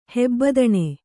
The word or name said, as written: ಹೆಬ್ಬದಣೆ